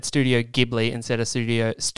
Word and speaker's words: Ghibli: It shall now be always pronounced Studio Ghibli.